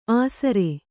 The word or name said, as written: ಆಸರೆ